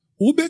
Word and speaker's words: Huber: Qual é a pronúncia certa do nome dele? Uber que você pega ali. Pega, pega Uber. É, é Isaías. É Uber, né? É, é, porque é com H, né?